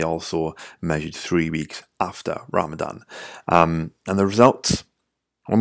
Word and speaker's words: Ramadan: They were done before Ramadan, um, they were done in the beginning of Ramadan, and uh, apologies, I definitely mispronounced Ramadan. It's Ramadan, um, at least if it's spelled correctly in this study.